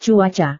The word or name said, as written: cuaca